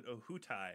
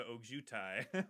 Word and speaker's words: Ojutai: I mean, living in California, I want to pronounce it Ohutai as opposed to Ojutai.